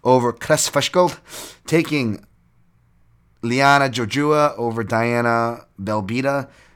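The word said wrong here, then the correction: Belbiță: Belbitsa, apparently, with a silent Z there, taking Jack Shore over Aaron Phillips.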